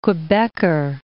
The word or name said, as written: Quebecker